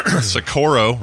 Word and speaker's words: Socorro: A team at the New Mexico Institute of Mining and Technology in Sirocco. Socorro, rather, I think is how you say it, is taking birds that have been preserved through taxidermy and converting them into drones in order to study flight.